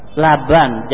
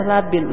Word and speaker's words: labin: Itu labin, bukan laban. Laban jadi bacanya. Pasti mestinya dibaca labin. Labin itu batu bata.